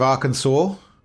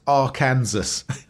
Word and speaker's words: Arkansas: Bill Clinton, he's the governor of Arkansas, which until I was like 30, I thought was pronounced Arkansas. So, yeah. I think it was Bill Clinton, basically him coming from there, taught me how to pronounce that place.